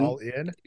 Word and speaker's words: Allin: All In? – Yeah, that really works. – Well, that's how it's spelled. – It's kind of like GG Allen, though. It's pronounced like GG Allen. It's Darby Allin.